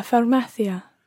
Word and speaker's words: farmacia: Una farmacia. – Try to make sure you're pronouncing this as farmacia and not farmacia, which is wrong.